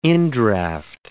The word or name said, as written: indraft